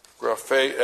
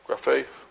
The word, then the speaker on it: graphē: Graphē, accent here on the last syllable, graphē, which means a graph.